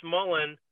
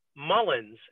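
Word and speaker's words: Mullin: He always pronounced Chris Mullen Mullins and put an S on the end of his name, which drove me crazy.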